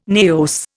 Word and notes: naos: Indian

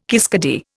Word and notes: kiskadee: Indian

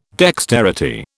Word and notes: dexterity: British English

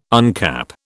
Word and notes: uncap: British English

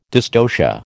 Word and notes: dystocia: American English

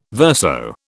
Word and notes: verso: British English

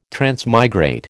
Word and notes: transmigrate: American English

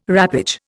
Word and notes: wrappage: Indian

